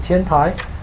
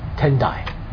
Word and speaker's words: Tendai: They pronounce it, instead of Tiantai, they pronounced it Tendai.